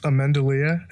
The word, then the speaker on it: Ammendolea: But it's Ammendolea, if anyone's wondering.